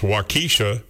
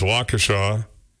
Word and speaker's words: Waukesha: And the great Les Paul in 1915 was born on this date in Waukesha, Wisconsin, which I've heard people pronounce Waukesha. – Yeah, sure. – But it's Waukesha.